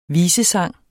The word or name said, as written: visesang